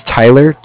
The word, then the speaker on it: Tyler: My name is Tyler, T-Y-L-E-R.